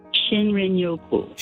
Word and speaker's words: shinrin-yoku: I think it's called shrin-yoku. Is that how you pronounce it? The Japanese term for wood air bathing. – Shinrin-yoku. – Shinrin-yoku.